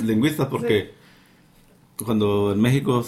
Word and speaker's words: México: Pues, nosotros lo conocemos como México. O sea, es como lo pronunciamos. – Con J.